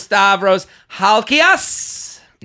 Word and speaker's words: Stavros Halkias: Here with Stavros Halkias.